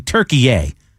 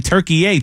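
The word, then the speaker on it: Türkiye: I knew they changed the spelling. I didn't know how to pronounce it differently. – Yeah, it's different again, apparently, I guess. Because, again, at least if the guy in SportsCenter was right, he kept saying Turkey A, Turkey A.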